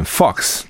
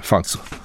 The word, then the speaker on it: Fox: 他说：“FOX 就是福斯新闻台啊，老美都念 Fox，对 Fox，我可能念的比较 Fox，Fox 啊，Fox 好。